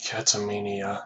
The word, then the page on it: catamenia: American English